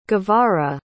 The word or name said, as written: guevara